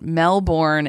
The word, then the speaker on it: Melbourne: Isn't, I feel like, so it's like when you try to say Toronto, it's Toronto. or like that's how they say it. And Melbourne is Melbourne, as far as I understand.